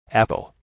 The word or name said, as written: apple